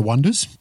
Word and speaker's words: Oneders: So I've gone with the 1996 film That Thing You Do, which revolves around a little pop band called The Oneders, but it's spelt with the O-N-E Wonders rather than W-O-N Wonders.